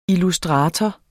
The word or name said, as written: illustrator